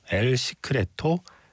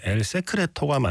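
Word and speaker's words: El secreto: 엘 시크레토 비밀의 눈동자. 이게 원래 스페인어 발음으로 하면 엘 세크레토가 맞는데 뭐 어쩌다 보니 이런 엘 시크레토라는 제목으로 국내에는 소개가 되었죠.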